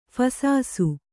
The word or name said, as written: ಫಸಾಸು